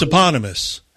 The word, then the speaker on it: eponymous: Well, I don't even know how it's pronounced. – It's eponymous.